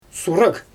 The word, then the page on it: suraɣ: Xyzyl